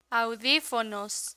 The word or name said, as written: audifonos